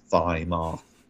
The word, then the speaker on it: Weimar: That's W-E-I-M-A-R. We pronounce it the German way, Weimar.